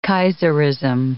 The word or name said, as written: kaiserism